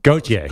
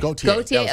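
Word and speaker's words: Gotye: This is how do you pronounce it? – Gautier. – Gautier, okay. – Gautier.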